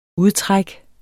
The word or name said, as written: udtræk